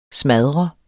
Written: smadret

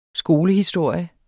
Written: skolehistorie

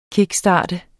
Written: KICKSTART

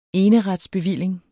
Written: eneretsbevilling

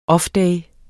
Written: offday

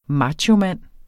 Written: machomand

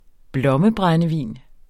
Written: blommebrændevin

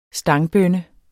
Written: stangbønne

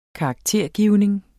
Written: karaktergivning

